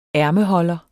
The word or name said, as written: ærmeholder